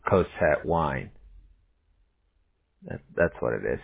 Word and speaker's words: Cosette: Is it, how you say it? Cosette? – Cosette, yeah. C-O-S-E-T-T-E. – E-T-T-E.